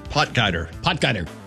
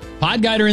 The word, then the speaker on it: Potgieter: Potgieter. – Potgieter in the lead.